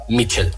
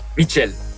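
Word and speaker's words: Míchel: His full name is Miguel Ángel Sánchez, but we all say to him Michel. – Michel. – Michel.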